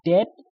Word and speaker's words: dead: And dead, it's said like bed, not bead. For goodness sakes, don't call it deed.